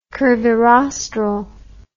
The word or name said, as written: curvirostral